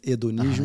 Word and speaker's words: hedonismo: In English, no, in Portuguese it's hedonismo. – Uh-huh, yes. – The H is silent.